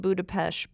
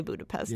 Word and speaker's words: Budapest: And I just want everyone to know, I know it's pronounced Budapest, but I don't want to. – Nah. – So for now, I'm going to say Budapest.